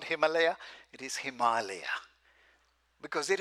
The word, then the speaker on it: Himalaya: But if you want to pronounce it right, Himalaya.